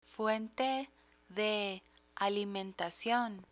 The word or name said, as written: fuente de alimentacion